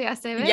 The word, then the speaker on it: Aceves: I'm so excited to introduce you all to the beautiful, wonderful Celia Martinez Aceves. Did I pronounce that correctly, Aceves? – Yeah.